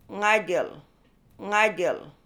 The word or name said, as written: Ngajil